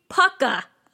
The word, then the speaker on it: Pukka: Puka, which is actually Pukka. I definitely was saying it wrong, which I had the sneaking suspicion I was saying it wrong, but I just carried on because I think Puka sounds funny. but Pukka T. I have since learned that that is the proper pronunciation thanks to the lovely Gemma Hardy on Facebook.